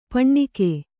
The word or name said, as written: ಪೆಣ್ಣುಗ